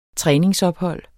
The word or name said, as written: træningsophold